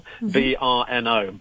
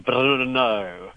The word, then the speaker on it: Brno: Difficult to pronounce because it's two consonants in the middle. Brno. Brno. I know that they roll that R, which I can't do very well.